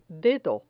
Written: dedo

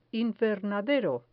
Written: invernadero

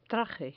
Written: traje

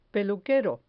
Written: peluquero

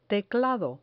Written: teclado

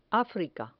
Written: africa

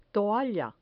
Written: toalla

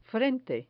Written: frente

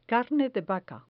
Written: carne de vaca

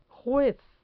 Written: juez

